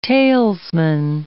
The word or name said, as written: talesman